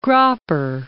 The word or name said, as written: Gropper